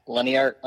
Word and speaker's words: Leniart: You can follow me on Twitter at Leniart underscore ND, and that's spelled L E N I A R T, not L E I N A R T, like that pretty boy from Southern Cal.